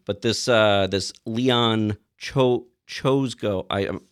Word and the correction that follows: Czolgosz: It's spelled Czolgosz, but it's pronounced Czolgosz.